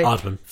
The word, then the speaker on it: Aardman: is it Aardman? – Yeah. – Is it pronounced that way? – Aardman, yeah.